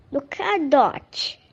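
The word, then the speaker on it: Luka Dončić: Como é que fala quando a gente fala dele? – Luka Doncic. – Exatamente, dele que falamos.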